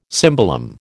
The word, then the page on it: cimbalom: American English